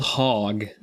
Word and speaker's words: Hogue: And one of them, which we will post the picture on social media, is called Hog. – Yes. – Like it's spelled H-O-G-U-E, but like it's a hog wine still.